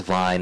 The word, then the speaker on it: Vyne: My name is Vine Henshaw.